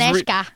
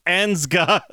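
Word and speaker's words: Anežka: I said, so it's Aneshka. I was trying to read it by verbatim how it was spelled, which is A-N-E-Z-K-A. So it's like, Anzga.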